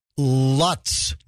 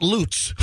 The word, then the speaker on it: Lutz: Yeah, the guy, Mr. Lutz, not Lutz. – With the beard. – spelled the same way, by the way. But the dude that killed his whole family. – George. – George Lutz, spelled the same way as Lutz here in the Tampa Bay area.